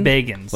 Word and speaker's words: Bagans: Is it Bagans? – Bagans. – Like he's begging for John Davis could definitely afford those.